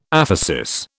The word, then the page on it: aphesis: British English